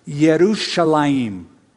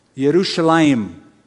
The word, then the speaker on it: Yerushalayim: It's Jerusalem, but the Hebrew pronunciation is Yerushalayim. Yerushalayim, the city of peace.